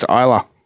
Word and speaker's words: Isla: I think it's Isla. – Isla. – Isla. – I don't know. – Well, I do. It's Isla. It's like Isla Fisher, the actor.